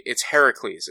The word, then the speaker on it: Heracles: Like, and it's actually Heracles is what how it's supposed to be pronounced.